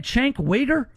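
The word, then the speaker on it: Cenk Uygur: Is that his name, Chink Wigger? – Yeah, I think that's how you say it.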